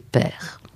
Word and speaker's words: Paër: Tandis que là, on en vient à cette dissociation, Paër, ce Ferdinand qu'on devrait appeler père.